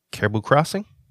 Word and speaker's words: Caribou Crossing: So I think number two is the Pendleton, and I think number one is the Caribou Crossing. Did I say that right? – You said that correct, yes.